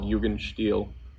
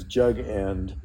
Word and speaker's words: Jugendstil: Somebody looking it up online, they'll look at it as jug end, but he's pronouncing it with the original language there.